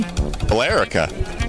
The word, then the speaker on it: Billerica: How do you pronounce it? There's an E in there. – Billerica. – Billerica, maybe? – Billerica. – Oh, that makes sense. Just leave out the E.